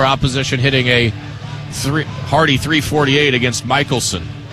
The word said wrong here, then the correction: Mikkelsen: But I believe it is Michelson. Now, here's how it's spelled. M-I-K-K-E-L-S-E-N. So it's kind of confusing.